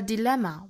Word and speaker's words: dilemma: Or 和 a 连读 or a dilemma we have to face. Dilemma, 发音注意。三个元音抓好。